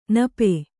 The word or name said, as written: ನಿಪ